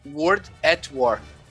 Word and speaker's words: World at War: Me ajuda aí na pronúncia, mano. World at War. Boa, é isso aí, galera.